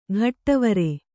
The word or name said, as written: ಘಟ್ಟವರೆ